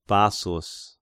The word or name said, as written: Bassus